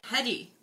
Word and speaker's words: Hedy: Spell that shit. H E D Y. – Heidi.